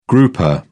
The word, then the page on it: grouper: British English